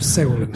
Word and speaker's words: Segulin: Kak se izgovara, Segulin ili Seguli? – Pa, Segulin.